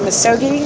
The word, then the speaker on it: Misogi: Is it Misogi? Is that how it's pronounced? – Yeah.